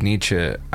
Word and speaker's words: Nietzsche: I feel that no batch of quotes from me would be complete if it didn't include something from my boy Friedrich Nietzsche. And yes, that is how you pronounce his last name.